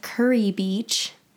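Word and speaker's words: Kure Beach: We were in Fayetteville, North Carolina for a couple days, and then we drove to a little beach called Curry Beach, spelled like Kerr, mm-hmm. – So it's a little confusing.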